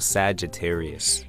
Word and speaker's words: Sagittarius: So Sagittarius.